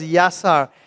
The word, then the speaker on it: yasar: And the way the word is used in this passage of scripture, it's very key for us to understand that this word here is a Jewish word that pronounced as yasar.